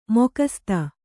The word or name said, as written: ಮುಖಸುತ್ತು